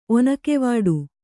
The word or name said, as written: ಒನಕೆವಾಡು